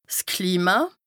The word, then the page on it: 's Klima: Bas-Rhin; Strasbourg